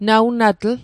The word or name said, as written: nawnatl